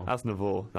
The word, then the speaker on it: Aznavour: Aznavour.